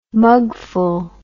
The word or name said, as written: mugful